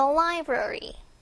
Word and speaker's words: library: in the library. library 요게 조금 발음 잘 들으셔야 됩니다. 라이브러리 아니고요. 라이 거의 라이브러리 뭐 거의 이렇게 들려요. library.